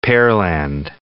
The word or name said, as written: Pearland